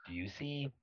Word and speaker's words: Bucci: there's like okay, so there's like an Italian pronunciation, which is like, uh-huh. – but then the American pronunciation, although I don't even know if that's like a real thing, is just Busey.